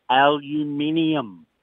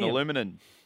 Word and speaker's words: aluminium: You cannot pronounce the word aluminium. – Oh, you mean aluminum. – Oh, don't you dare. Don't you dare. Seriously, if I hear another person say aluminum, I'm going to do I don't know what. But seriously, surely it's spelt aluminium. I cannot handle when Americans say aluminum.